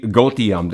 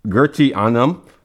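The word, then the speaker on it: Goetheanum: Like, and when I first read it, it spelled out to me Gotium. That's what it spelled out to me, you know. But it was actually pronounced Gertianum. And I'm still working on that as we speak right now.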